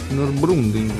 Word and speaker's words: Nürburgring: Nürburgring.